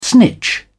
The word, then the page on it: snitch: British English